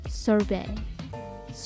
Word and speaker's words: sorbet: 語で言うソフトクリームはソフトスーブソフトスーブと言いますソフトスーブでシャーベットみたいなもののことをソルベイ